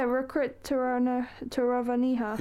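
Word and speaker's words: Rirkrit Tiravanija: Is it Tiran Javia? I probably butchered it. Anyway, look, the art piece is called Untitled. Oh, you can say it. – Yeah, Rikrit Taravaniha.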